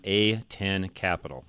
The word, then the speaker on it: A10 Capital: It's A as in Adam, A10 Capital. I want to make sure I say that right, Todd. – Yep, you got it.